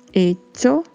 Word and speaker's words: hecho: Lembrando que se fala com o som de uma palavra que estamos habituados a falar, que é tchau. Então pronunciamos dicho, hecho, trecho.